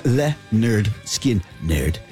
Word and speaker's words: Lynyrd Skynyrd: Freebird from Lynyrd Skynyrd, pronounced Lynyrd Skynyrd.